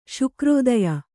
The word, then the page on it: ಶುಕ್ರೋದಯ: Kannada